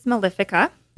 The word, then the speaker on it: mellifica: Well, there's a remedy made out of the honeybee called Apis mellifica. – Yeah. – And is that how you say it? Mellifica? – Yep. Oh. That's how I say it. – I say mellifica.